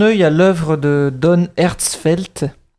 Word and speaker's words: Hertzfeldt: Alors, Herzfeld, c'est ah. écrit H-E-R-T-Z-F-E-L-D-T.